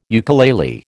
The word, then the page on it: ukulele: American English